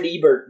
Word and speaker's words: Ebert: It's not, you know, Roger Ebert was not goth, so he's not going to like it. Or did I just say his name right?